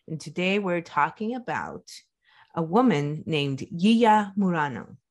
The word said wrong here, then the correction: Yiya Murano: Oh, I'm sorry, I'm saying that wrong already. I'm saying it how I spell it, but it's actually Yiya Murano. – Mm. Oh. – Yeah. – So the Y is a J? – It's kind of like a Z.